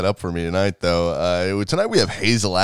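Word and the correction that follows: Hasil: Atkins, or Hassel Atkins. – It's actually pronounced Hassel. – Is it? It's H-A-S-I-L.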